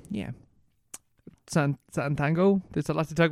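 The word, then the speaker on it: Sátántangó: Satan Tango. Yeah, that's how you pronounce it. – Yeah, Satan Tango.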